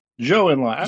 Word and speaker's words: Zhou Enlai: Zhou Enlai.